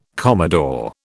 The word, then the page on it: commodore: British English